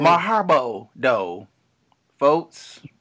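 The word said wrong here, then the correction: marhaba (مرحبا): I'm pronouncing marhaba, which is hello in Arabic.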